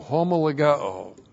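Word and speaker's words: homologeo (ὁμολογέω): H-O-M-O-L-O-G-E, long O, homologeo.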